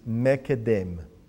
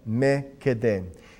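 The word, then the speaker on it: Mekedem (מִקֶּדֶם): The Hebrew phrase here is Mekedem. And it's spelled from right to left, Mem, Kuf, Dalet, Mem. Again, Mekedem.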